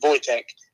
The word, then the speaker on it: Wojtek: Right, but according to Google, it's pronounced Wojtek.